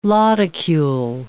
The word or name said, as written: lodicule